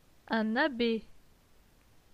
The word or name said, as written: Un abbe